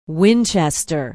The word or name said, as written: winchester